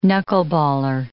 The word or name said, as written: knuckleballer